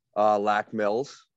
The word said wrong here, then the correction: Mille Lacs: Is that how you say it? – Mille Lacs. Other way around.